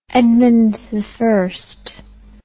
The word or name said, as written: edmundi